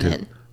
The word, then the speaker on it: Colcannon: Colcannon.